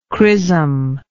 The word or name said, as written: chrismal